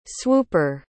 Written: swooper

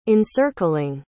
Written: encircling

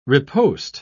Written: riposte

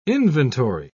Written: inventory

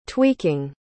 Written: tweaking

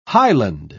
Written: highland

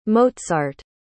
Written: mozart

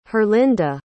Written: herlinda

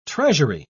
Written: treasury

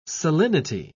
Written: salinity

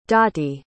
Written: dottie

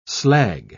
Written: slag

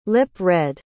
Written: lip-read

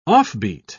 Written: offbeat

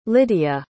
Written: lydia

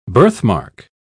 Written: birthmark

